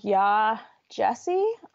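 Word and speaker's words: Yaa Gyasi: Okay, my first is Homegoing by Ya Jesse, I believe is how it's pronounced. – You nailed it.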